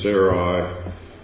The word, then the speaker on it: Sarai: It says, now Sarai, There's a couple of pronunciations for this. I'm going to use Sarai.